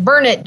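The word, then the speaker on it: Burnet: So everyone made sure we knew how to pronounce it. Burnett, darn it.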